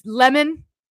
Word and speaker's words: Lemon: Is it Lemon or is it Lemon? I've been calling him Lemon. I want to say like this was a thing a few years back. Like people were mispronouncing his name and he got real butthurt about it. I'll have to look it up. But somehow I pulled out the Lemon. But it's Lemon, like lemonade, like sour lemonade.